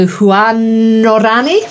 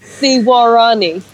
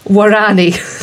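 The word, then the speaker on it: Waorani: the Huanorani? – No, you're wrong. the Warani. – Warani. – Yes.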